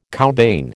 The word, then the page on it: cowbane: American English